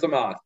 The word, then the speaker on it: Omos: Osmos, right? – I think it's Amos. I think they're going with Amos. – It is Amos. Yeah, Amos.